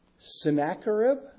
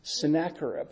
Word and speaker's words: Sennacherib: Sennacherib. Is that really how you say it? Sennacherib? That's not how I say it. Boy, I'm way off. I guess, yeah. I'll call him up and ask him. Say it again. Sennacherib. – The CH has a K sound in the Bible.